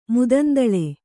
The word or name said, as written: ಮುದಂದಳೆ